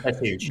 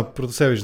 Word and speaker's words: Протасевич: Протасевич ли, как е ударението, ти ще кажеш? – Протасевич. – Да, Протасевич, да.